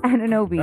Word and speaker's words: Anunoby: So before I butcher his name, because I always do, I can say Antetokounmpo like nothing, but OG Anunoby. – Anunoby. There you go.